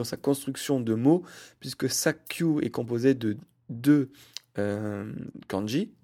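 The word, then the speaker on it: Sakyu (砂丘): Donc euh, bon, au niveau de la prononciation, c'est pas très logique euh, de faire Suna plus Oka qui fait Sakyu, mais en tout cas, au niveau signification, quand vous associez colline plus sable, ça fait dune de sable.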